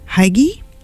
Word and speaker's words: Hygge: Hygge, H-Y-G-G-E,